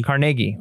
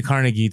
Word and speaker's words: Carnegie: Carnegie. But it's, you can say Carnegie too.